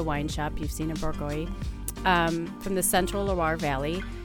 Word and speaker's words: Bourgogne: That's your Bourgogne, and that's how you say it. It looks like Bourguignole.